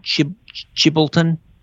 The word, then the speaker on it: Chilbolton: Chilbolton.